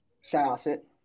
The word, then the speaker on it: Syosset: Syosset.